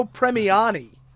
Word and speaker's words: Premiani: Arnold Drake and Bruno Palmieri? Parmesan? – Don't ask me how to pronounce his name. – Gene Parmesan, how you doing? – Gene Parmesan, Bruno Premiani.